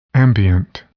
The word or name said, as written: ambient